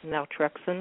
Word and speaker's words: naltrexone: Now, in your book, you talk about low-dose naltrexone, if I'm pronouncing it correctly.